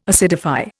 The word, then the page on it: acidify: Indian